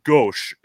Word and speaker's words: gauche: And gauche is how they pronounce it, right? – Yeah, it's the French for left.